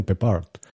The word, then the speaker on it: Peppard: Que por cierto, preparando un poco la película, me he enterado que los ingleses lo pronuncian Peppard. O sea, con un acento en la A.